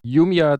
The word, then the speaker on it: Jumia: Spricht man die so aus? Oder Jumia? – Jumia, Jumia, ja.